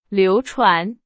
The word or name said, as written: 流传